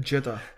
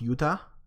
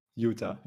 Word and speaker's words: Jutta: Another one saying Jutta. I think in The Witcher 3, her name is pronounced Yuta. – Yuta, yeah. I apologize.